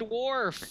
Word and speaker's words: Worf: It is indeed Worf.